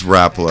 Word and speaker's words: Rapala: It is Rapala.